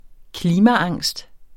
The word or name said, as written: klimaangst